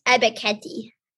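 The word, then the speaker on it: Ebiketie: I have Devin Lloyd, the linebacker out of Utah, and Arnold Beckety, the edge rusher from Penn State, both being drafted by the Chiefs. Devin Lloyd at 29 and Arnold Beckety at 30. – I think it's pronounced Ebeketti.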